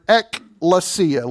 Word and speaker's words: ekklesia: It is the Greek word ekklesia. Let me spell it for you so that you'll understand this. E-K-K-L-E-S-I-A. Ekklesia. E-K-K-L-E-S-I-A.